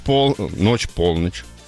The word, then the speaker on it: полночь: Так, Елена: ночь полночь. Вот так она даже поправила ударение на вторую.